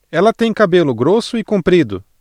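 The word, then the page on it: grosso: Brazilian Portuguese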